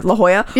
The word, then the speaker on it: La Jolla: La Jolla? – Yes. – Oh, fuck. – La Jolla. – La Jolla.